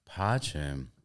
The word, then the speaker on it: Pace: They have Pache. – It's like P-A-C-E. Pace is like how it's spelled.